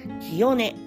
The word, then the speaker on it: 澄音: 1 月 19 日金曜日、水道橋ワーズさんで、えー、企画名がミカワーズプレゼンツ、清音、えー、澄んだ音漢字で澄んだ音と書いて清音